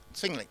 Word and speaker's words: Zwingli: Zwingli.